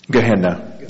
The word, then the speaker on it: Gehenna: Gehenna, yeah.